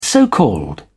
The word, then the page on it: so called: British English